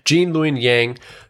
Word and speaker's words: Gene Luen Yang: And now he is finally, you know, being written by Asian American writers, Asian Canadian writer Gene Luing. Oh gosh, I'm butchering his name. Gene Luen Yang, who is a very famous writer.